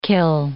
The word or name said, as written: kill